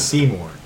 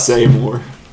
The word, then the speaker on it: Seymour: Seymour is how you say that. – Okay. Because of the butt. – Seymour. – Well, there's a Y. S-E-Y. Se-M-O-U-R. Muar.